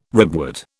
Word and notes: ribwort: British English

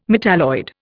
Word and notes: metalloid: Indian

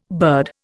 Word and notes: bird: Indian

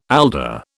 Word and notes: alder: British English

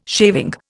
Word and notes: shaving: Indian

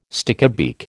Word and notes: stickybeak: American English

